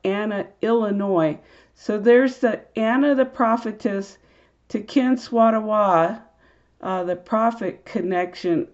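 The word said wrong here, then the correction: Tenskwatawa: I think I mispronounced his name, but again, Tecumseh's younger brother is Ten Swatawa,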